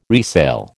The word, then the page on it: resale: American English